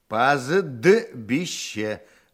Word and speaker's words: пастбище: «Пастбище». Я не слышу «д». – А, по-моему, здесь не произносится согласный «т». «Пастбище». Правильно, Николай Владимирович? – Не знаю.